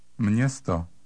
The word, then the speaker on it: Město: This combination is pronounced with an N sound inserted between the M and the hooked E. Listen and repeat. Number 14. – Město.